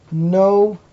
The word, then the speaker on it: Noach: It's the only letter like this, that if the A is underneath this letter and it's the end of a word, instead of pronouncing it Cha, we pronounce it Ach, as in the word Noach.